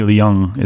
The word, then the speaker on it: Leung: Although I think Tony Leung is actually correct. I think it is actually his 2 syllables, but I could be crazy about that.